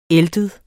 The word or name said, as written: æltet